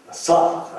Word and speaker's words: 萨婆诃: 这个萨婆诃这个现在藏语的音译呢，它原译作梭哈梭哈，有一则梭哈，有一则梭沃哈。这个萨婆诃呢，这个按照现在的翻译读音呢，你看哈，应该是梭哈。刷还是正确一些，刷，而且那个哈的，不能看，这个汉语里边呢很难发音，发出这个哈这个音，它跟汉语的哈是不一样的。